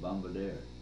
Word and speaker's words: Bombardier: Bombardier. That was the proper pronunciation.